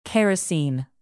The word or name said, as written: kerosene